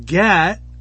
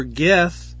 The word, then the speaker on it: Geth: And Gat or Geth, as it's pronounced in the Greek, is Strong's number 1660, and it means press, wine press, wine fat.